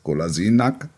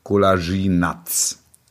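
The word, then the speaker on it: Kolašinac: Golasinak, er heißt jetzt nicht mehr Nack, sondern Natsch. Und jetzt, Achtung, dringende Mitteilung an alle Redaktionen. Spieler heißt jetzt Golasinatz.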